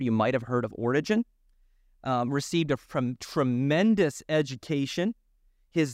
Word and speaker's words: Origen: Origen, That's how you would pronounce that.